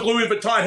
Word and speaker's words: Louis Vuitton: I have nothing to do with Louis Vuitton.